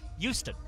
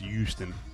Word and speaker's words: Houston: There's no H. – Houston. – It's just Houston. Like Y-O-U.